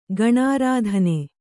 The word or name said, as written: ಗಣಾರಾಧನೆ